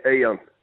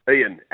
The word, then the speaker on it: Ian: How do you say his name? – Yeah, Ian. Ian, as in Ian.